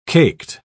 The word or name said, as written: caked